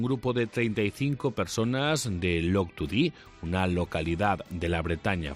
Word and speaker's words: Loctudy: Ya me ha corregido aquí el compañero, ¿eh? Porque no sabía yo muy bien la pronunciación de esta localidad de la Bretaña francesa. Es Loctudy, ¿eh?